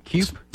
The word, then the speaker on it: Keup: They're going to be starting tonight Shane K-E-U-P. Would you pronounce that cape? Kipe? – Keep. Spelled again. – Keep. K-E-U-P.